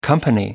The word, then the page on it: company: American English